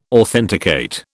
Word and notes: authenticate: British English

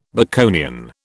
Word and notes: Baconian: British English

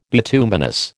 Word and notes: bituminous: American English

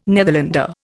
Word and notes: Netherlander: Indian

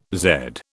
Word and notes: Z: British English

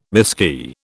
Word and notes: miskey: British English

